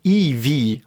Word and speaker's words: Evie: Ähm, selbst der Name Evie, ne? also wie die weibliche Hauptfigur heißt. Ne? Ähm, e ist der fünfte Buchstabe, also Evie, der Name wird ja IV, also EV ausgesprochen.